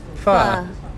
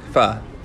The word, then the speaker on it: pho: Which, by the way, is that really how you say it? – Pho. – Pho. – Yes. Instead of pho. – Yeah, that's what I thought it was. – Some people say pho. I think the Vietnamese pronunciation is pho.